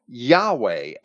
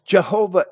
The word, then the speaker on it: Yahweh: Jehovah is a modern mispronunciation of the Hebrew name, resulting from combining the consonants of that name, which they have as J-H-V-H, but it's Yod, and it's better translated as an I or a Y.